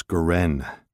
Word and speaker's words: Goren: Mrs. Goren, that's Goren. The accent's on the last syllable, but Anna is fine.